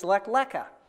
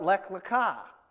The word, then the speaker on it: Lech Lecha: If Nate were standing here, he would say, our portion today is Lech Lecha. And I call it Lech Lecha. And the difference is where we put the accent on the last Hebrew word.